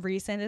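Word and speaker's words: Rhysand: Rescind, I like to say Rescind, but Rescind is apparently how you say it.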